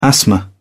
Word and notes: asthma: British English

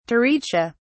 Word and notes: taricha: British English

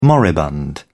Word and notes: moribund: British English

